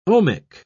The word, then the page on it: ohmic: British English